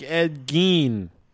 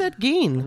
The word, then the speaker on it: Ed Gein: I like Ed Gein, because I'm pretty sure that's how it's supposed to be pronounced. – I'm pretty sure that that's correct. I think it's Ed Gein.